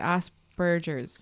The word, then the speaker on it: Asperger's: So when Nathan was young, he's diagnosed with Asperger's. Am I saying that right? Asperger's.